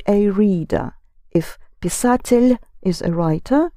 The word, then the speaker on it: писатель: Make sure you pronounce писатель soft in the ending. Писатель.